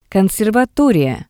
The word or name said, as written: conservatoriya